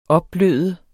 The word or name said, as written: opbløde